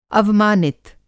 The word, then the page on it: अवमानित: Hindi